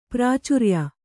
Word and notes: ಪ್ರಾಚಾರ್ಯ: kannada

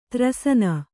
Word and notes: ತ್ರಸ್ನು: kannada

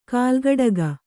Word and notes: ಕಾಲ್ಗಡಗ: kālgaḍaga

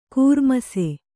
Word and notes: ಘೂರ್ಮಿಸು: ghūrmisu

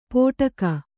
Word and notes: ಪೋಟಕ: kannada

